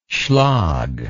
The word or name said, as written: schlag